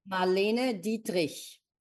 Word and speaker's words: Marlene Dietrich: Marlene Dietrich.